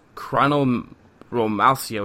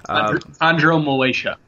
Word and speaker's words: chondromalacia: Yeah, I believe Mo Williams' actual injury was chondromalacia, which I'm totally mispronouncing. – Chondromalacia. – There you go.